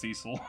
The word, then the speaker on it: Cesyll: It's spelled C-E-S-Y-L-L, but it's 100% pronounced Cecil.